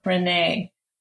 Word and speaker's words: Renae: Well, you know, my most favorite pink climber is Renee, which is spelled R-E-N-A-E.